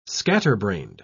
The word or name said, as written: scatterbrained